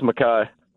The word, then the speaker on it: Makai: It's pronounced Makai. – Makai. Okay. Like Cobra Kai, but my Kai.